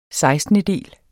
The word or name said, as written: sekstendedel